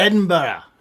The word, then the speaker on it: Edinburgh: Oh, Edinburgh. – Like one extra right. Yeah, you go. You were just missing one more syllable. That's it. – Edinburgh.